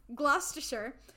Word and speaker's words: Gloucestershire: In June of 1548, she moved to Studley Castle in, I know how to say this, Gloucestershire. Like Worcestershire. Gloucestershire. To await the birth of her child. I think that's how you say it.